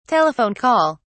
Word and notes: telephone call: British English

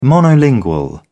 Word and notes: monolingual: British English

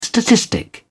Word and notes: statistic: British English

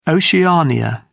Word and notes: Oceania: British English

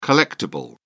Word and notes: collectible: American English

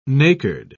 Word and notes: nacred: British English

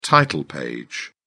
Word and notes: title page: British English

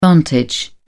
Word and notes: vantage: British English

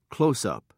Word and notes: closeup: British English

